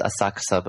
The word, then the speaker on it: Asakusa: It's pronounced Asakusa, but when people see it in guidebooks, they always say Asakusa.